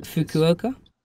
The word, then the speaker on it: Fukuoka: It's in their hometown, was that Fukuoka? – Yeah. – Yeah, I can never pronounce that one. It's got the U and the O. – Fukuoka? – Yeah.